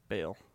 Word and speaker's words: Bale: His name rhymes with the country. And also with Jail. – What you make out of hay. – Bale.